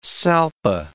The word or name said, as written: salpa